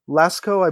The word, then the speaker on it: Lascaux: Am I saying that right? Lascaux? – Lascaux, I believe, is correct, yes.